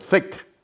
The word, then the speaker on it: PHEIC: 咁啊喺 1月22號、 23號開會裏邊，當時 22號大家都估計世衛會將呢個嘅武漢肺炎咧列為呢個 PHEIC，叫做 THIC，同 FAKE 嘅發音係差唔多嘅，會列為呢個國際關注公共衛生緊急事件。